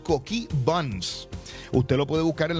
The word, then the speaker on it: Coquí Buns: Coquí Buns, no deje de ir a Coquí Buns en Aguadilla, lo busca así. Coquí, se escribe Coquí como el de esto, B-U-N-S, Buns, Coquí Buns.